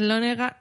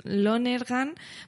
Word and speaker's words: Lonergan: Tenemos a Damien Chazelle, este maldito de 32 años por La La Land, Kenneth Lonergan, mm, uy, qué mal lo pronuncio, Lonergan por Manchester by the Sea, no voy a decir frente al mar porque me parece fatal.